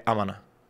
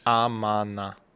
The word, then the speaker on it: amana: E tem um super abreviado que é amana. Amana speak. – Tá, que eu não sei nem como é que se escreve isso. Amana. – Amana. – É A-M-A-N-A.